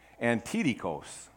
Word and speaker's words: Antidikos: Well, it comes from the Greek word antikos. Now let me say that again because I didn't say it right. Antidikos. Antidikos.